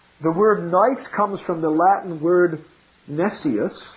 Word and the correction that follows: nescius: or actually it should be pronounced nescius, which means ignorant.